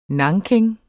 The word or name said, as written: NANKIN